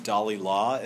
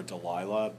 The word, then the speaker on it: Dalilah: Dalilah, as Milton calls her. We call her Delilah, but Milton is using a different pronunciation of her name.